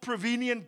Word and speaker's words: prevenient: This one is called prevenient grace. Okay. Prevenient grace. Must I spell it for you? P-R-E. V-E-N-I-E-N-T. Got it? P-R-E-V-E-N-I-E-N-T.